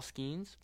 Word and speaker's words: Skenes: Uh, with how do you say it, Paul Skenes? – Yeah, yeah.